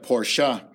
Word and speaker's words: Porsche: Si usted es dueño de Porsche, ya sé que está ofendido porque se pronuncia Porsche, no Porsche. Aquí para los inalfabetos es Porsche, pero para los que saben es Porsche.